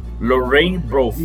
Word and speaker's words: Lorraine Broughton: Lorraine Broughton.